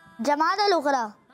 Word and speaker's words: جمادی الاخری: جمادی الاخری اس کا صحیح تلفظ ہے پیارے بچوں, جمادی الاخری.